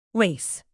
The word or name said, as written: lace